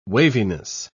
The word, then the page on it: waviness: British English